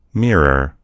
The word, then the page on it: mirror: American English